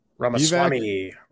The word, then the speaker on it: Ramaswamy: And it is this gentleman who's I'm familiar with him, but I always butcher his name. What is it? Vivek Ramaswamy. – Ramaswamy. Yeah.